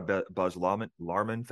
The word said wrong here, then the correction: Baz Luhrmann: fan. Is that how you say it? – Baz Luhrmann.